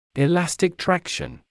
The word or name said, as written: elastic traction